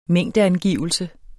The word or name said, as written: mængdeangivelse